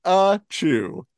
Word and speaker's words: Pikachu: Pronounced Pikachu.